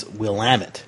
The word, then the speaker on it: Willamette: And then it's Willamette. – Willamette. – Willamette. Well, yes, because it rhymes with damn it. – That's pretty close Willamette.